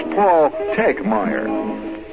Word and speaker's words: Tegmeyer: He says the, si- the second T is silent. So it's Paul Tegmeyer. Very silent T in there.